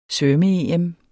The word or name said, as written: svømme-EM